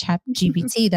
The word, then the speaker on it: ChatGPT: And that's ChatGPT, that I feel that I really need to entertain it now.